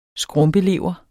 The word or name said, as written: skrumpelever